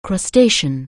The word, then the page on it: crustacean: anglais